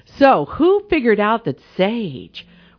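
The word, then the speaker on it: Säje: Their group is pronounced Sage, rhymes with beige.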